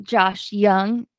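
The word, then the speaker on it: Josh Jung: Okay, so Josh Young, is that how it's pronounced? – Yep.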